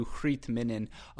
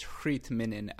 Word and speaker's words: Greet Minnen: But to Hrit Minnan, again, that's what it is. It's Hrit Minnan, just that's the pronunciation. I checked it in my head. That is correct.